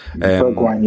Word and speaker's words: Bergwijn: You know, they signed the guy, is it Bergwijn? Is that how you pronounce it? – Bergwijn, yeah.